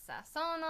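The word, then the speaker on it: sazón: I bought this seasoning, and there's this seasoning called sazon.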